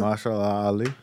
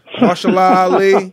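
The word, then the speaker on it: Mahershala Ali: What's the kid? Marshall Law or whatever? – Marshall Ali. – Marshall Ali.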